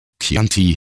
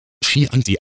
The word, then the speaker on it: Chianti: Und wenn man dann noch das Glück hat, einen Gast zu erwischen, der meint, Chianti, der Rotwein, wird Chianti ausgesprochen, dann könnte ich denen allein schon wegen ihrer Dummheit und Hochnäsigkeit eine verpassen.